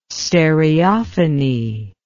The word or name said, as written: stereophony